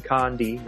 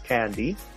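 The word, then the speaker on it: Kandi: The first fact being that she pronounces her first name Condi, not Candy.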